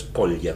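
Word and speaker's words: Kolla: Mire, hace poco se cumplió un nuevo aniversario de la marcha Colia, según me han dicho la forma correcta de pronunciarlo es Colia, con K y L, ¿no?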